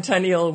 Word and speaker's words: Tenniel: And I read a poem where they rhyme Tenniel with perennial. – So I'm okay. That's clearly how you pronounce his name.